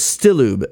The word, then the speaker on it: Stilloob: And it the title was Stilloob, S-T-I-L-L-O-O-B. And someone eventually cracked it that it's bullets backwards, at least phonetically, it's bullets backwards.